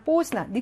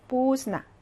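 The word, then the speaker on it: 뽀스나 (поздно): 그다음 뽀스나 늦게고요. 발음이 묵음이 하나 있죠. 뽀스나 대가 묵음입니다. 대가 묵음.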